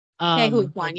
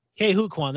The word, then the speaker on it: Ke Huy Quan: If you don't know who Ke Huy Quan was, I hope I'm pronouncing his name right. – Ke Huy Quan, yeah. – Ke Huy Quan, that's it.